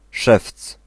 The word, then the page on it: szewc: /ʂɛft͡s/